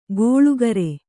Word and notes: ಗೋಳುಕರೆ: gōḷukare

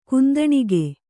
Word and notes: ಕುಂದಣಿಕೆ: kundaṇike

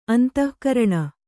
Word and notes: ಅಂತಃಕರಣ: antahkaraṇa

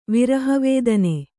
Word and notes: ವಿರಹವೇದನೆ: Kannada

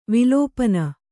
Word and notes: ವಿಲಪನ: vilapana

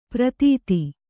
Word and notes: ಪ್ರಥಿತ: kannada

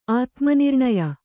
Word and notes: ಆತ್ಮನಿರ್ಣಯ: ātmanirṇaya

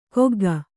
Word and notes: ಕೊಗ್ಗ: kannada

